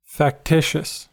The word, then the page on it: factitious: American English